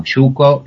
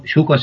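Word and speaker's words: Yuco: Lo más fácil para comunicarse conmigo son las redes sociales, Estoy en Facebook como Yuko. Yuko se escribe Y-U-C-O y mi apellido es Di Lelio.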